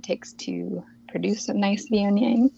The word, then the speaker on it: Viognier: So it is not Wagner or anything like that. It is Viognier. I've also had seen it as Viognier, so a different vowel, but Viognier is going to be a safe pronunciation and the correct one.